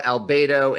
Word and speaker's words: Albedo: You talk about the three steps being, and I'm going to mispronounce this, you talk about Negretto, Albedo, and Ribetto. – You did pretty good.